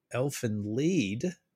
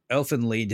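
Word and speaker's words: Elfen Lied: I think, yeah, you will never get the jump scares, but I want to try, and I feel like Elfin Lied, if I'm saying that correctly. – You are saying that.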